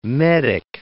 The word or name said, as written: medic